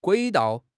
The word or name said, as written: 轨道